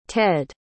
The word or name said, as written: tedd